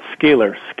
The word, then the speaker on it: Scalar: Now, did I say that right, Scalar? – Let's call it Scalar.